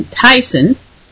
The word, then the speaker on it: Thyssen: Now, last week I was pronouncing his name Thyssen, which I have now learned is something closer to Thyssen, which I will try to say it a little bit better. And that's who I mean when I say Thyssen. I mean the one that whose name looks like it's pronounced Thyssen in English.